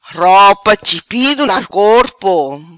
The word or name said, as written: Ropa chipido na corpo